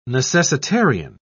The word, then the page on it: necessitarian: British English